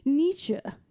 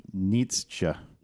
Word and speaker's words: Nietzsche: I'm assuming this is a German name, and um, in German you would pronounce it Nietzsche. Cool. But in English, for some reason, a lot of people that I hear say this name, they do say Nietzsche, but you would not pronounce that E at the end if we're looking phonetically at the German language.